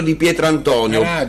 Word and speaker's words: Di Pietrantonio: Poi a Nicola Caradio, a Ernesto Di Pietro. Come ho preso l'accento qua? Inaspettato. Ernesto Di Pietrantonio.